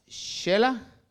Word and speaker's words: Xela: mi esposa decía Chela, pero le digo yo no, es Chela. Ya me molestaba después porque me dice, dice que se pronuncia Chela, pero luego me dijo que otro lugar se pronuncia Misco cuando yo leo que dice Mixco.